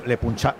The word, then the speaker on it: Le Puncheur: Le Punché. ¿Cómo se escribe Le Puncheur. – Le Puncheur. – Eso es.